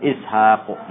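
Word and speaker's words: Ishaqu (إسحاق): Nah, ini Ishak itu walaupun tidak tulis dengan panjang tapi cara bacanya dipanjangin Ishaqu gitu.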